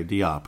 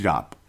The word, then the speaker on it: Diop: I can't believe how many people say Diop. Call him Jop. It's Jop.